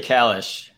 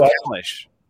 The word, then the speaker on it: Kalish: We got Joseph Kalish at 12:15 from – You got Joseph Kalish? How'd you get Joseph Kalish? – From Anachart? Okay. – Yeah, no, I'm serious. – It's actually Kalish.